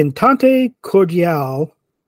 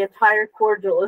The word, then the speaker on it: entente cordiale: No, I read it as the entire cordial is sealed. – Oh, yeah, no, you definitely read it wrong. – Yeah, that's why when you said it, I'm like, what? I'm like, No, entente, yeah, I pronounced that correctly, but I don't know if that's just cordial or it's supposed to be cordial or whatever.